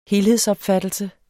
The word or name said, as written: helhedsopfattelse